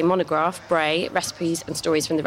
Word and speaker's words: Brae: Bray.